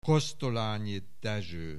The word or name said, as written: Kosztolányi Dezső